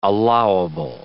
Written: allowable

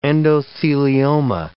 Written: endothelioma

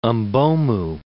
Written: Mbomou

